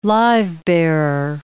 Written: live-bearer

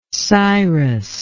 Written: Cyrus

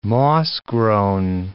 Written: moss-grown

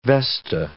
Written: vesta

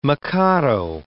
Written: mikado

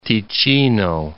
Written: Ticino

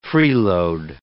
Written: freeload

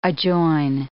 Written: adjoin

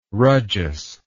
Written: rajas

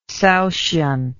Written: Saoshyant

